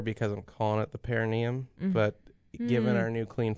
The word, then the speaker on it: perineum: Perineum. – Perineum. There we go. I cut a syllable out, but close enough.